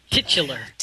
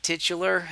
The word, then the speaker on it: titular: How do you say that? I always want to say tit for some reason. – Titular. – Titular. There you go.